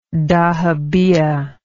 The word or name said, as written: dahabeah